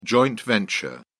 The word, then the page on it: joint venture: British English